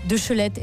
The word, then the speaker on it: Deschelette: Bonjour, Emmanuel Deschelette.